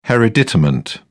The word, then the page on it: hereditament: British English